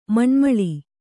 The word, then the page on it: ಮಣ್ಮಳಿ: maṇmaḷi